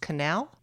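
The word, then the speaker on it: Kanell: Is it pronounced Cannell? – Yes, Jenna Cannell.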